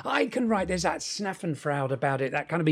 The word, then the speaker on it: Schadenfreude: Schnaffenfreude. The German Schadenfreude. – Yeah.